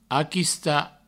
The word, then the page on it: aquistar: aquista